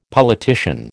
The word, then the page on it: politician: American English